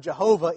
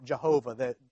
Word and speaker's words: Jehovah: So you take the consonants from Yahweh and the vowels from Adonai, put them together, and you spell out Jehovah. The Y becomes a J in the Germanic pronunciation there.